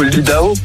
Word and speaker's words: Idaho: Mais l'Idaho, c'est plutôt l'Idaho. – Ah, mais tu l'as bien prononcé, je t'en prie, je sais pas, tu sais, je n'ai pas eu ta raison. – À l'américaine, parce que comme vous le savez, je suis de Californie, moi.